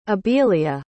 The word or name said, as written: abelia